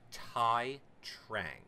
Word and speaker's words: Tai Trang: Now, how do we say his name? I'm not, is it like Tay Tai Tayo? – I think in English, in American English, it's Tai Trang. – Tai Trang. – Correct.